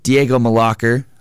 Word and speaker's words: Diego Mlakar: With us today, making his Sports Zoo debut, is Diego Mlakar. Hope I didn't butcher that. – No, you're good.